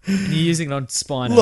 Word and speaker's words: Spiner: And it's Spino. If we're getting a, pronunciation, it's Spino. – It's definitely not. That's Spinner. – No, it's not. The two N's is Spinner. – Yeah, that's how you spell it. Let's look it up. Let's Brent Spiner. Data from Star Trek. – Yeah, I know. – It's Spiner. One N.